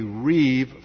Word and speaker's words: riv: In fact, Deuteronomy 32 is written according to what is called in the Hebrew a Reeve format. Just spell that R-I-V.